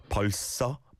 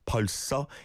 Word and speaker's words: 벌써: 벌써? 벌써? Yeah, because it's the 상시옷 on the next syllable. There is a bit of a 스 on the first syllable at the end, right?